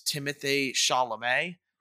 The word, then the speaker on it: Timothée Chalamet: The lead role who plays Paul is Timothée Chalamet.